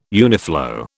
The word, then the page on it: uniflow: British English